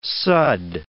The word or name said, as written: sudd